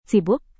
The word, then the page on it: sibuk: Indonesian